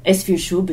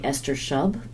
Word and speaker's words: Esfir Shub: Esfir Shub is a very good pronunciation of that Soviet name. An American would probably say Esther Shub, but Esfir Shub is how she would have been known.